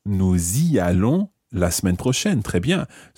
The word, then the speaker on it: nous y allons: And notice that we pronounce the s of nous y allons. Okay, the nous has the s pronounced here because it's followed by a vowel sound.